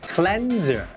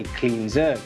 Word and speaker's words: Cleanser: ครับเพราะ Cleanser แปลว่าผลิตภัณฑ์ทำความสะอาดผิวและต้องออกเสียงว่า Cleanser. ไม่ใช่ Cleanser.